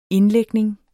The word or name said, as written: indlægning